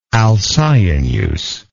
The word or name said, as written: alcyoneus